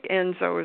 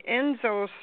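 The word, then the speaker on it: ensō: I think Enzo is the correct pronunciation. It's E-N-S-O.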